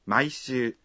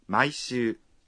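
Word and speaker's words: Maishu (毎週): Maishu.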